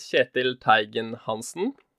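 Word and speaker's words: Kjetil Teigen Hansen: Hi, my name is Chetil Teigen Hansen. I like to pronounce it in the Norwegian way.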